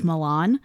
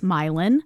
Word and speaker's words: Milan: Because she told us, she goes, I know it's spelled like Milan, but we say Milan kind of like Versailles.